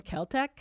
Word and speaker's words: Celtx: No, I wrote it in Celtec. It's spelled Celtx.